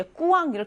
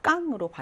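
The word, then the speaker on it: quand: 그다음 Q는 철자 Ü에 음가가 없음에 주의한다라고 해서 껑도 이제 꾸왕 이렇게 발음하시면 안 되고요, 깡으로 발음하시면 되겠어요.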